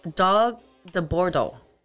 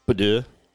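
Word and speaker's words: Dogue de Bordeaux: And, by the way, the pronunciation. It is Dog de Bordeaux. – Bidet. – Not bidet.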